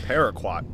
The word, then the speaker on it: Paraquat: It's Paraquat Pesticide. I think that's how you pronounce it. – Paraquat.